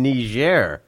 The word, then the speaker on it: Niger: Yeah, what about the soldier that got killed in Niger? You should give me points for pronouncing that correctly. no one ever talks about on the news what the fuck were American soldiers doing in Niger in the first place on some secret mission that they got killed.